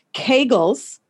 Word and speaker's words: Kegels: But she never had a baby, but because she was told to do, and I found out how to pronounce it, Kegels. It rhymes with bagels.